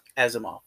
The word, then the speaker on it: Asimov: You have the Captain Isimov, who is supposed to be a descendant of Isaac Isimov. – Asimov? – Asimov, thank you.